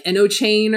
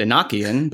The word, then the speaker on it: Enochian: It's like Enochian, where we were saying like Eno chain or whatever. – I just call it enchiladas. – Enchiladas. Yeah, okay. – She said that. – I I pronounced it Enochian, but okay. Yeah. Enochian, sure.